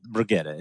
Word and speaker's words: Brigitte: Well, Brigitte, it's her name isn't pronounced Bridget because she's like not from the States.